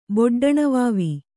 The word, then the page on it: ಬೊಡ್ಡಣವಾವಿ: kannada